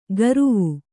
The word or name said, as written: ಗರುವು